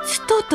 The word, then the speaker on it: 土産: 今朝の俳句母の日に旅のつともてこの来たる母の日に旅のつともてこの来たる磯尾千代子漢字で土産と書いてつとと読むんだそう